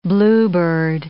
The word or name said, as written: bluebeard